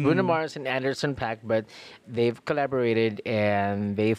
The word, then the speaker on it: Anderson .Paak: Anderson Paak.